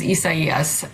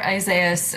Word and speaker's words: Isaias: I mean, the actual correct pronunciation is Isaias. – Hey, all right. – Yeah, for those of us in the U.S., you'll hear Isaias,